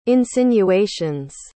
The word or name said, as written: insinuations